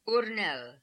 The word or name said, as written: Oornel